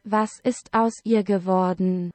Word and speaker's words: Was ist aus ihr geworden: And in German, it's pronounced, according to Google Translate, Was ist aus ihr geworden? – There you go.